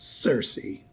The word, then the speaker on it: Circe: So every English dictionary you can find, OED, Oxford English Dictionary included, suggests Circe is the proper pronunciation.